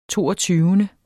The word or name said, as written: toogtyvende